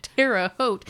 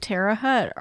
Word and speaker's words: Terre Haute: Terre Haute is T-E-R-R-E. And it doesn't matter because we don't say it right anyway. Terre Haute is French, and we're not even kind of close to saying it correct,